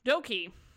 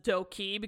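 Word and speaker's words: Dok2: All right, Doki, the first co-CEO, which is pronounced as Doki because the way two is pronounced in Sino-Korean is E. So you're wondering why it's Doki. So he is a Filipino-Korean who was born in the States and debuted independently in 2008.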